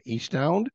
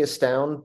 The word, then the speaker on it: Estound: We have Eastound. Is that, am I pronouncing that correct? Is it Eastound or Estound? – You know, I generally say Estound, but I don't correct people who say Eastound.